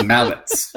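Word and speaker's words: Malec: Mallets is pronounced T-S. Thomas Mallets. – Well, it all depends on who you talk to. But you probably have the correct pronunciation.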